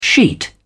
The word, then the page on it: sheet: British English